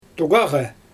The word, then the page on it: tuɣaɣa: Xyzyl